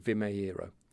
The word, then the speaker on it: Vimeiro: I think that's how you say it, Vimeiro, spelt V-I-M-E-I-R-O.